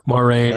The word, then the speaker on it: Moiraine: Like I said, I call her Moiraine. Is that how you say it? – Yeah. Moiraine or Moiraine.